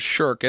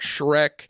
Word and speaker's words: Shirk: People butcher mine, believe it or not, and it's only five letters, Shirk, S-H-I-R-K, but I get called all kinds, Shirk, Shrek, Shrek, you get all kinds of crazy stuff.